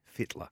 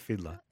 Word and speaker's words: Fittler: Yeah, Freddie Fittler. – Yeah, he was down there. – And there was one other. – It's Fittler. – Fittler. – No, it's not Fiddler.